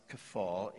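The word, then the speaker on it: kafal (κεφαλή): It is kafal, uh, pronounced kafal in the Greek.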